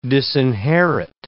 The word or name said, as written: disinherit